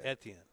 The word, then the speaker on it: Etienne: The French pronunciation would be Etienne.